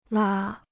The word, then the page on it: 拉: lā